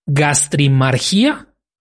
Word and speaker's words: gastrimargia: Gastrimargia, con acento en la I, casi, y dos puntos, ¿no?